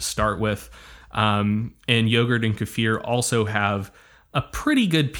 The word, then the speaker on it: kefir: which you might call kefir. I learned that I was pronouncing that wrong. It is kefir, or a mixture of yogurt and water.